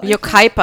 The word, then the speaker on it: Yucaipa: And we go to a lovely bar called Celebrities in Yosipa. Did I say that right? – Yucaipa, I think it is.